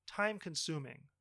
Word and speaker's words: time-consuming: The stress should be on the second syllable in consuming. Time-consuming.